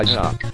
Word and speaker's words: Xoc: And last up, we have The Goonies 2 and In Theme Song, covered by Zoc. That's spelled X-O-C but pronounced Zoc.